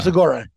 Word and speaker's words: Segura: Segura? Is that how you say his name? – Yeah. Tom Segura.